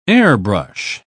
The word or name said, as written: airbrush